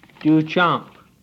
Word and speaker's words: Duchamp: I named the dog Duchamp with a long A.